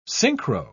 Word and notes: synchro: British English

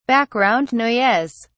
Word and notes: background noise: British English

